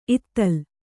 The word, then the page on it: ಇತ್ತಲ್: Kannada